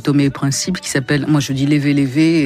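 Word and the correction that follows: Léve Léve: et ça se prononce pas comme ça. Comment ils disent là-bas? – Lévi Lévi. – Lévi Lévi. Très bien.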